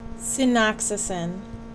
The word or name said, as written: Cinoxacin